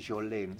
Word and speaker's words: Jolene: Hay un tema de ella que creo que se pronuncia Jolene. Se escribe Jolene.